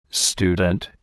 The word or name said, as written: student